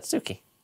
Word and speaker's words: Tsuki: Suki, spelt with a T. – How do you spell Suki with a T? – T-S-U-K-I. – Oh, Suki. – That's what I said, Suki.